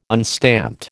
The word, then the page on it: unstamped: American English